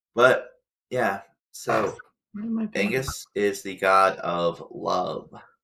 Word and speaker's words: Aengus: How would you pronounce this, Kelsey? I'm going to do this to you. – Okay. – Just because this is fun. A-E-N-G-U-S. – Agnes. – Angus. – I was close.